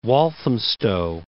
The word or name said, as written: Walthamstow